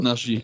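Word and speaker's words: Naji: von Jamal Nagy spricht man ihn, glaube ich, aus. – Nagy, genau, ja.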